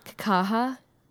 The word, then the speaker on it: Kakaha: Kakaha? – Kakaha.